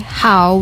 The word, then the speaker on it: how: Yeah, make sure you don't say how with a flat o sound because the correct pronunciation is how, not ho.